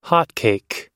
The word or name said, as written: hotcake